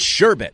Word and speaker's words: sherbet: For someone who doesn't think much of sherbet, you sure have a lot of strong opinions on how it's pronounced. It's sherbet.